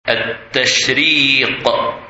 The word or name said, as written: Tashriq